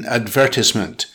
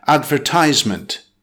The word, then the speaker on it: advertisement: Sentence example, in British English, emphasis is put on the second syllable in advertisement, whereas American English emphasizes the third syllable, advertisement. Next, psoriasis.